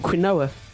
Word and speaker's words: quinoa: I just thought it was called quinoa. Apparently it's pronounced quinoa.